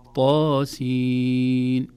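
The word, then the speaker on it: طسم: إذن نقول طاء وليس طاء، هي حرف طاء وضاء، لكن عند نطقها في أوائل السور تحذف الهمزة يصبح حرف ثنائي مد فقط، طا ويا حا را هكذا، نقول طاسين.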